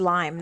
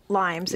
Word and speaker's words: Lyme: You know, there's a ton of different myths about it, and just to clarify for all of you, Lyme is pronounced Lyme, not with an S at the end. – Right. – It's not Lymes.